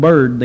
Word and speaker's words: Biard: According to the Byrd family records, and it is B-I-A-R-D, and they pronounced that Byrd then, according to the Byrd family records, Brother Stone came and visited there when his niece's husband was killed.